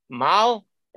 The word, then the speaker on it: Mahle: And the Reds have a pitcher, Tyler, how do you say it? Mahle. Yeah.